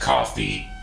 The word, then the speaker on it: Ko-fi: It is pronounced coffee. – What? This? It's spelled K-O-F-I.